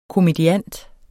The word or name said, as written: komediant